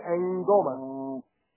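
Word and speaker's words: ngoma: It's N-G-O-M-A. It's pronounced Angoma.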